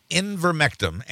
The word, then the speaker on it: ivermectin: Ivermectin.